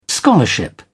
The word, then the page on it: scholarship: British English